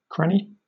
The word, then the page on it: cranny: British English